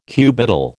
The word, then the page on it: cubital: American English